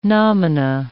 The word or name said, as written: nomina